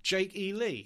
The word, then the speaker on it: Jake E. Lee: And he settles on a new guitarist, Mr. Jake E. Lee. I think that's how you pronounce it, isn't it? – Jake E. Lee? – Yeah.